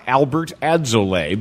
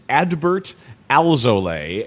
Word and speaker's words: Adbert Alzolay: It's not Albert Alzolay, because it's very easy for your brain to transpose to something that much more familiar. It is Adbert Alzolay, and he's putting together a season at AAA for the Cubs.